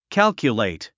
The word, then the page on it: calculate: kǽlkjulèit; カルキュレイト